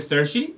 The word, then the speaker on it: Saoirse: O sea, el hecho de que, de que la, la, actriz que conocemos por su nombre como Saoirse se pronuncia Sershi es como irlandeses.